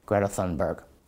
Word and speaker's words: Greta Thunberg: And by the way, yes, that really is how you pronounce her name. I'm always amazed at people who are super woke on climate, multiculturalism, and everything else, and then say they're big fans of a person called Greta Thunberg. When she introduced herself to the UN, she said Greta Thunberg, more or less, and I assume she knows how to say her name.